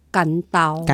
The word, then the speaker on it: 官渡: 叫这个地名，咱应该是爱读作官渡。 – 官渡、其实这个所在啊，叫伊个古仔，诶，读法都是干刀。